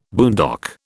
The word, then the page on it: boondock: British English